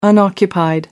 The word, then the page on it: unoccupied: American English